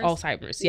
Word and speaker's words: Alzheimer's: Alzheimer's, yes.